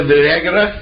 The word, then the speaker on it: Vergara: Aunt Gloria, Sophia Vergara.